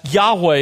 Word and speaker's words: Yahweh: That's Yahweh is how it's pronounced in Hebrew.